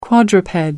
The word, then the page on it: quadruped: American English